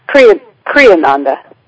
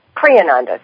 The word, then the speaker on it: Kriyananda: Did I pronounce that correctly, Asha? – Kriyananda. – Kriyananda, excuse me.